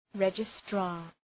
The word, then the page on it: registrar: 'redʒı,strɑ:r